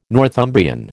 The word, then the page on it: Northumbrian: American English